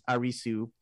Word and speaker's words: Arisu: Like the main character, his last name is Arisu, which is how you say Alice in Japanese because there's no L. So A-R-I-S, and then there's no C-E in Japanese. So you would say S-U.